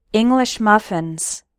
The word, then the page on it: English muffins: American English